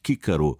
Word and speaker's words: Cicero: Im klassischen Latein also zu Zeiten von Cäsar und Cicero, da wurde das C wie ein K ausgesprochen. Cicero heißt eigentlich Kikaro, was nichts anderes bedeutet als Kichererbse, ein Spitzname für den großen Philosophen und Staatsmann, der eigentlich Marcus Tullius geheißen hat.